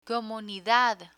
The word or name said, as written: comunidad